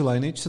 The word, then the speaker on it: Lineage: Já si myslím, že to asi Lineage se to vyslovuje.